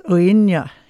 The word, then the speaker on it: Uaine: That was Uinya, is the name of the band. It's spelt U-A-I-N-E.